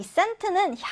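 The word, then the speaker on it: scent: C 발음이 나지 않고 그냥 S 발음을 해주시면 돼요. Scent인데요. 이 scent는 향기, 냄새를 얘기합니다.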